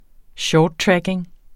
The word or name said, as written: track